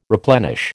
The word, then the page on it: replenish: American English